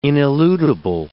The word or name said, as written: ineludible